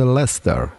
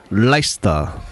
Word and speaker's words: Leicester: Tra l'altro, l'immenso Gianfranco Giubilo ci spiegava già tanti anni fa che non si dice Leicester, ma si dice Leicester. – Leicester.